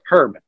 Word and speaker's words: herb: Herb. – He pronounces with a H. – Fabulous. – Excellent. We're off to a great start. – Oh, no. There's people in the U.S. just drop their H's.